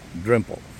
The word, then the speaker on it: Dalrymple: It is this journalist from BBC called Anita Anand, and the most famous, the unique William, as he names himself, William Drempel. It's written Dalrymple, and that's how we pronounce it, and that's obviously not the right pronunciation. It's William Drempel.